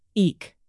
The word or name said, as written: eke